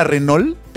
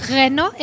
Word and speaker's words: Renault: No es la Renault. – Pero les voy a decir algo. Bueno. Renault en francés. Nosotros Renault. Estamos en México y podemos decir Renault.